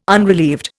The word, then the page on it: unrelieved: Indian